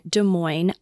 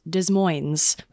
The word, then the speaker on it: Des Moines: Fun fact, I just learned how to say Des Moines today because I thought that you pronounced the S's this whole time. I thought it was Des Moines.